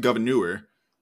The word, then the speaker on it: Gouverneur: There's a town near where I grew up called, we pronounce it Governor.